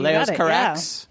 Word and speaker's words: Leos Carax: am I pronouncing it right? We were discussing this before we went on the air. You looked it up. What is the correct pronunciation? – That was it. Leos Carax.